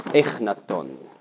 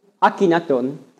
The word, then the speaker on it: Akhenaton: Vous voyez ici le pharaon Echnaton. Vous dites comme ça? Vous prononcez différemment? Akhenaton. Voilà.